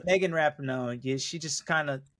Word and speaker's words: Rapinoe: It's their last one, and it's Rapinoe. Dude, get it right.